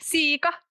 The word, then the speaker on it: siika: huge embarrassing mistake, I remembered for the rest of my life how do you say whitefish like siika in Finnish, and how do you say pig, which is sika?